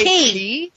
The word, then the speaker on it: quay: But how do you guys pronounce the word quay? Do you guys say key? – Yeah.